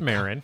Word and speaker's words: Maron: Mark Maron, if I'm pronouncing that correctly, and the boom. – That's Maron. – Mark Maron, excuse me.